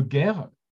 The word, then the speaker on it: ger: et là, je vais le prononcer à la française pour pas trop faire style hébreu. Hein. il y a le guerre, ça, s'écrit en, hébreu G-E-R si on veut le, le faire maintenant.